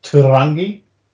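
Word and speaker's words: Tūrangi: Is that near the town pronounced Turangi? – Yes.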